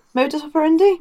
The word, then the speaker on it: modus operandi: You know, that's part of kind of our modi operandus. I'm saying that wrong, aren't I? – modus operandi? – There we go.